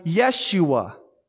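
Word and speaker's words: Yeshua (ישוע): Because the Hebrew name for Jesus is Joshua, which we would have pronounced in that time Yeshua.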